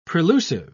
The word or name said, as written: prelusive